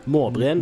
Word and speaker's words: Maabren: Hvordan skal jeg uttale det? M-A-A-B-R-E-N. – Måbren.